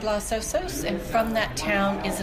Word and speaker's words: Los Osos: How do you pronounce it? O-S-O-S Vineyard. – Los Osos, which means the bears in Spanish.